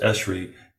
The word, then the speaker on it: ESHRE: When you read the ESHRE, it's pronounced ESHRE, guidelines, it says laparoscopy is no longer the gold standard for diagnosis of endometriosis.